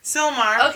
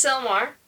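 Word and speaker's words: Sylmar: How do you say this? – Slimar? – Silmar? Silmar? Oh my goodness. – Silmar. – Okay, it's Silmar.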